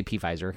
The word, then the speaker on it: Pfizer: Pfizer, by the way, I always say Pfizer because there's a silent P in front of it, revealed last week that its vaccine appeared to be 90% effective.